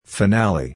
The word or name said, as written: finale